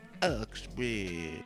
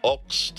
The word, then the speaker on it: Oxbridge: What be so special about Oxbridge? – Ox Tarquin rhymes with socks and fox.